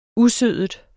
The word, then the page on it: usødet: ˈuˌsøˀðəd; ˈuˌsøːðəd